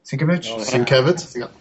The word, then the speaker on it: Sienkiewicz: Bill Sienkiewicz. – Sienkiewicz.